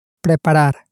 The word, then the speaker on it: preparar: Preparar. – Preparar. – You can hear two R's at the end there, so it can be a little bit difficult to pronounce.